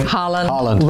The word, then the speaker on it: Haaland: Haaland.